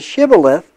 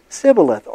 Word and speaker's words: Shibboleth: And so the closest the Ephraimites could ever come to Shibboleth was Sibboleth, or burden. And their pronunciation was a dead giveaway.